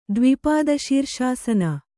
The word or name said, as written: ದ್ವಿಪಾದಶೀರ್ಷಾಸನ